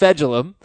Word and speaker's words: Fejedelem: F E J E D E L E M, pronounced Fegidellum. I'm ignoring him.